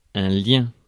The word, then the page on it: tie: /taɪ/